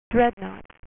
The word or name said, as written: Dreadnought